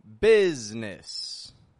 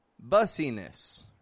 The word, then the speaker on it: business: Now, the second word that I'm going to bring to your attention here today is the word business. Business. B-U-S-I-N-E-S-S. Business. It is spelled like bussiness, right? Bussiness, B-U-S-I-N-E-S-S. But the pronunciation doesn't come out bussiness, just comes out business.